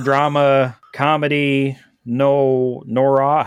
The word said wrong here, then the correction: film noir: How do you say that word? – Film noir yeah, that thing.